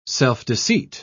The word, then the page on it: self-deceit: British English